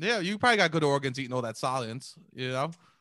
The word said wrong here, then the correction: Soylent: Yeah, man, couldn't even pronounce it right. – Soylent. Yeah, what was Salient? – Oh, man.